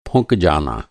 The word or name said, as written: फुंकना